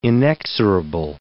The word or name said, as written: inexorable